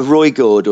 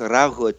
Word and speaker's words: Ruigoord: For, uh, Roygord or something. Yes, yeah. We, we're, we're, just pronouncing it very wrong, I believe. – But yeah, yeah, but it's pronounced Ruigoord.